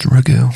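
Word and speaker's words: ragù: I think it's ragu. – It is pronounced ragu.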